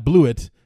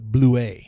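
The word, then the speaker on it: Blewitt: Not Blewett, the way it's spelled, but Blue is how it's supposed to be.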